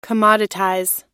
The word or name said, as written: commoditize